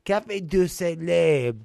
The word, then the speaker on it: Café du Soleil: Café du Soleil. You have to say it like you are French.